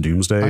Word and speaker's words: Domesday: It's spelled like Domesday Duplicator, but I believe it's pronounced Doomsday.